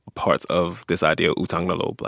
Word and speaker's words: utang na loob: Utang na loob. Did I, did I say that right? – Yes.